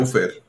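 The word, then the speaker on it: búfer: Buffer existe en español, lleva acento y tiene una sola F. Claro. Buffer. Lo siento, Aldo. Toma tu buffer. Ahí está. Toma tu buffer. – Pero con acento en la U.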